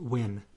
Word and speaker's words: Nguyen: Yeah, sometimes referred to it or pronounced it Win.